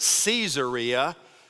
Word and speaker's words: Caesarea: They call it, when you go over there, they pronounce it Caesarea because it's named after Caesar Augustus, which points to its Gentile nature.